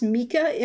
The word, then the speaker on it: Mika: Have you heard of this musician named, I think it's pronounced Mika, M-I-K-A.